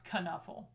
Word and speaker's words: knuffel: So the word knuffel comes from the Dutch. And it is pronounced knuffel.